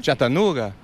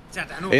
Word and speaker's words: Chattanooga: ¿Cómo se pronuncia exactamente? Y lo dicen un jamaicano, un colombiano, pero que viven en Estados Unidos. – ¿Ese nombre? – Chattanooga. – Chattanooga. – En español, Chattanooga. – Es Chattanooga.